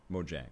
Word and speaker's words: Mojang: Mojang, right? – It's Mojang. – Mojang. – Mojang. – How does PewDiePie say it? No, I think the Mojang. – Mojang, bro. – Yeah. No, I think the Mojang thing,